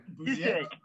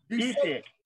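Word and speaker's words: Busiek: I know I picked up the, is it Busiak or Busek? How do you pronounce his name? – Busek. – Busek. I like the confidence with which you said it, so I'm going to say it the same.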